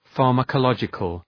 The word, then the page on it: pharmacological: ,fɑ:rməkə’lɒdʒıkəl